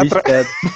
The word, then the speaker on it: obstetra: O obstreta. Obst... – Obstretra. – Obstetra. Obstetra. Pronunciei errado, galera.